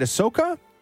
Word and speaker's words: Ahsoka: They had 10 new Star Wars shows, including the, how do you say, is it Ahsoka? – Yeah.